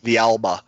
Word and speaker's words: Villalba: Where we're going to have to, shore things up is going to be in the defense, especially – with Atlanta having Hector Villalba, I never can say his name right. – Villalba, and then Almiron.